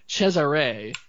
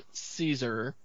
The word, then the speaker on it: Cesare: This guy, now I've heard his name, his first name is pronounced Cesare, not Caesar.